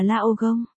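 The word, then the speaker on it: lao gông (老公): Lão cung và lão công aka chồng, đều có cùng một cách phát âm là lao gông.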